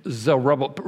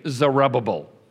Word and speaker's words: Zerubbabel: Okay, now you need to know how to pronounce this name. Zerubbabel. Repeat after me. Zerubbabel. Zerubbabel.